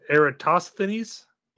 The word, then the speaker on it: Eratosthenes: Are you guys familiar with an ancient Greek known as, I'm gonna mispronounce this, Eratosthenes? – Close. – Eratosthenes? – There you Eratosthenes.